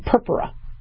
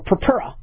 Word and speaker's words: purpura: Okay, you know, we don't want anybody to spontaneously bleed, but we call those bruises in medical terminology purpura or purpura. All right, I always put my accent on the wrong thing, but I called it purpura, and I think they corrected me as purpura.